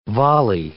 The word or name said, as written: volley